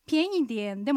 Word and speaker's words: 便宜点: 便宜点でも便宜点でもどっちでも安くしてっていう意味になります。 – アクセントは二声、軽声、三声の組み合わせです。